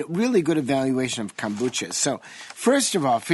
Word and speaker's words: kombucha: For some reason, Zorba, we're talking about kombucha. – Kombucha. – Now. – You know, you pronounced it right. You got it right.